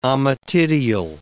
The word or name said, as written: ommatidial